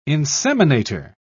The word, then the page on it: inseminator: British English